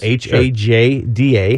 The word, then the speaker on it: Hajda: My name is Nick Haida. – Of course, it's spelled H A J D A. Hajda, if you're nasty.